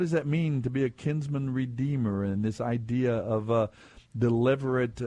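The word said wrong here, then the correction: levirate: I don't know how to even say it. Is that, how do you say that? Leverate marriage? – That's good. – What is it? Yeah, that's good. I can get by with that.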